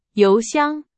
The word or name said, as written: 邮箱